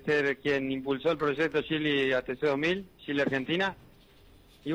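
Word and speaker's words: Geely: Porque es el desembarco de la primera marca china, Yeli. ¿Así se pronuncia? – Sí, efectivamente, es Yeli, como se pronuncia.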